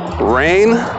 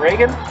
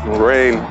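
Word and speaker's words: Reign: Rein, but it's spelled R-E-I-G-N, I think. – It's not Reagan? – Rein.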